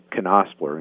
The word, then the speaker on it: Knospler: Knospler, and it's K-N-O-S-P-L-E-R, and that's how they say it. Whenever I say it, people say, are you sure that's how you're supposed to say it? But it is.